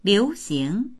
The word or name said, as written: liu2xing2